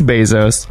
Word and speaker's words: Bezos: It's Bezos.